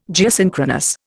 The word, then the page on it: Geosynchronous: Indian